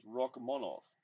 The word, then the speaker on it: Rakhmonov: I heard him say his name the other day, and that's why I'm calling and why I'm saying it that way. It's Rakhmonov. Shavkat Rakhmonov.